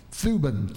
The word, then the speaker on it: Thuban: Now, the name of the star is, if I can say it right, Thuban, T-H-U-B-A-N, Thuban.